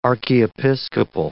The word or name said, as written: archiepiscopal